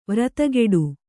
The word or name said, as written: ವ್ರತಗೆಡು